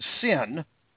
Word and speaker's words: CIN: The initiative known around the agency as the CIN, C-I-N, pronounced sin.